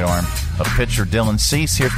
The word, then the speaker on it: Dylan Cease: Dylan Cease. – I got it right. – Dylan Cease. – I said Dylan Cease.